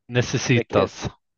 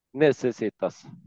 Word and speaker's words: Necessitas: Och tilläggas var ju det här namnet som ingen kan uttalas, eh, nummer två, Dira Nekesitas eller eh, någonting ditåt. – Necessitas. – Necessitas.